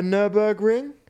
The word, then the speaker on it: Nürburgring: I believe at the Nürburgring.